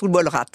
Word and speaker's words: Ubolratana: Ça se prononce Ubolrat.